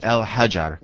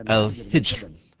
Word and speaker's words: al-Hijr: Some people mispronounce it al-Hajar, but this is wrong. It's called al-Hijr, because Hijr was a mountain.